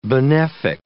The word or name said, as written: benefic